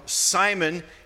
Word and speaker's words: Simon: Simeon is the Greek pronunciation. I'm sorry, I said that wrong. Simon is the Greek pronunciation, and Simeon is actually our pronunciation of the Hebrew transliteration of his name.